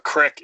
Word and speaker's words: creek: A crick is actually a creek. It's just pronounced strange in this one state.